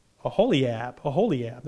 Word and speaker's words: Oholiab: And then you have God choosing two people, Bezalel and Oholab. However you want to say that for us. How do you say that? Oholiab. There we go.